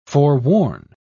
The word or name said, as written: forewarn